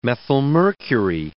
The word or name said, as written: methylmercury